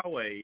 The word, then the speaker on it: Huawei: So as far as the brands of smartphones, and I hope I pronounce all these right, so there's Huawei, which is Huawei? – Is it Huawei? – I think that's like that. – Huawei, yeah.